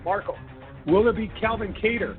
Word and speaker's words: Kattar: Jeremy Stephens, he will take on Calvin Kattar. I don't know why we used to butcher his name and call him Qatar. Apparently, some people still do, but it's Calvin Kattar at a minus 255 favorite.